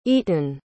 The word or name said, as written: Eitan